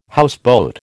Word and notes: houseboat: American English

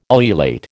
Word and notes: ululate: American English

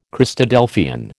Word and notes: Christadelphian: American English

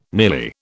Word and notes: nearly: British English

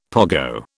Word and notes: pogo: British English

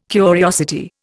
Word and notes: curiosity: Indian

